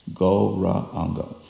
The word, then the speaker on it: Gauranga: Gauranga. Four syllables. Gauranga. So sure enough,